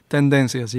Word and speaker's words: Tendencias: The debut album is Tendencias. Did I say it correctly? – Tendencias, yes.